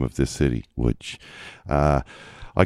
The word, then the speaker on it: Łódź: I'm in Łódź, which of course is spelled L with a little line through it, O-D-Z. But it's pronounced Łódź.